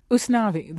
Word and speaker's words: Usnavi: Usnavi, the name of your protagonist, which is spelled U-S-N-A.